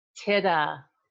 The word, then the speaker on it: Tida: Is it Tita? Tida? How am I saying it? – Tida. – Tida.